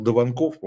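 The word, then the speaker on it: Даванков: А вот удивительно, что на втором месте был Дованков, он правильно так фамилия произносится, из новых людей, которые дали экзит-пол, дали 20%.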